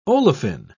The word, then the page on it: olefine: British English